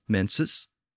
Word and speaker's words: mensis: Now, Latin also developed a word from that same original Indo-European root word, mensis, and the Latin word was very similar, pronounced mensis, and this word is the root of menstruate and menstrual in modern English, again referring to a monthly cycle.